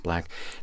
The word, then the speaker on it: 6LACK: Black is the artist. That's 6 L-A-C-K, pronounced Black.